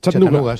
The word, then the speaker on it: Chattanooga: Chattanooga, sí.